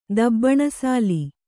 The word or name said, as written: ದಬ್ಬಣಸಾಲಿ